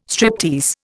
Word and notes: striptease: Indian

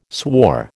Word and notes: swore: American English

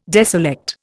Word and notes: deselect: Indian

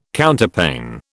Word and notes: Counterpane: British English

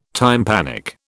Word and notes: tympanic: British English

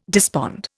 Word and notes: despond: Indian

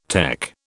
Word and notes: tech: British English

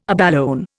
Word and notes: abalone: Indian

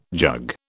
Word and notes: jug: British English

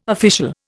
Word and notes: official: Indian

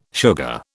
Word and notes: sugar: British English

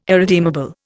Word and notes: irredeemable: Indian

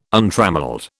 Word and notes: untrammelled: British English

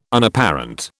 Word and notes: Unapparent: British English

